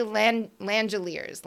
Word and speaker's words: Langeliers: So we're just going to go with rhymes with chandeliers. And we're going to say Shea Langeliers.